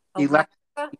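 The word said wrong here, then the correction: Elektra: Electra Records, yeah.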